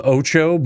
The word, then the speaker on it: Otxo: It's called Ocho, but it's spelled O-T-X-O.